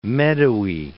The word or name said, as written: meadowy